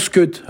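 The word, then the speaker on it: e-skud: Mais en fait, ça s'écrit « e » avec un petit tiret « skud », mais ça se prononce « skud », hein, on n'entend pas le « e » qui est avant, quoi.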